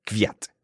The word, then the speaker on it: Kvyat: I really, really don't like your pronunciation of Kvyat at all. – I'm pretty certain it's Fiat. Supposed to be pronounced Fiat. – I've been assured that it's Kvyat.